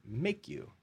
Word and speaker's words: Maku: So anyways, it turns out Maku, M-A-K-U, is Hawaiian for risk. And so we said, well, what if we put a line over the A and we pronounce it Make You?